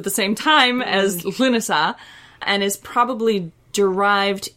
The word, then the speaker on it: Lughnasadh: Uh, so it's spelled L U G H N A S A D H. It is pronounced Lunasa, and it is spelled much closer to that in modern Irish.